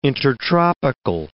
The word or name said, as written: intertropical